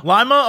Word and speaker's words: Lima: even though Paul was in Lima, I don't know how to pronounce that town in Ohio. – Lima, Ohio? – Well, yeah.